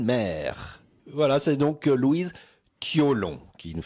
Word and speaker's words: Thiolon: It was self-titled, so Louise, Tion? – Toilon. – So that's spelled T-H-I-O-L-O-N. – Toilon. Toilon, sorry.